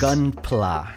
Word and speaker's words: Gunpla: Gunpla. I don't know if I pronounced it correctly, but you got it.